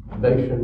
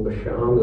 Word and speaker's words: Bashan: The land still, Bashan or Bashan, as they would pronounce it, still has that character of lush pasture land.